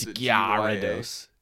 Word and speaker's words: Gyarados: It's Gyarados. It might be Gyarados. – Gyarados. – I pronounce it like the same way I pronounce Euro, but obviously the G is not silent.